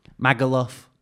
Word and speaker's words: Magaluf: How do you say Magaluf? – Magaluf. – You say Luff. – Magaluf. – As if you're saying Loughborough.